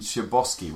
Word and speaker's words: Chbosky: It's worth mentioning Stephen Chbosky, which is incredible.